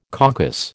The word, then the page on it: coccus: American English